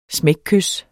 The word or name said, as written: smækkys